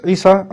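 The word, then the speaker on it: Isa (عيسى): Jesus, Isa, as he's pronounced in Arabic,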